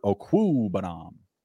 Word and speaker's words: Okwuegbunam: Gerald Everett or Albert Okwuegbunam? – Man, that was a nice pronunciation there.